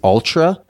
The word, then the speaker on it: Altra: I think it's Altra. – Uh-huh. – A-L-T-R-A, maybe? – Yep.